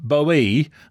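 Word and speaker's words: Boe: Have I pronounced your second name Bow correctly, Alfie? – You have, yes, very, very correctly. That's fine. – It's because it's B-O-E, and it was catching me out a little bit, to be honest yeah, with you. – How do you pronounce the word toe? – Yeah, that's a very good answer.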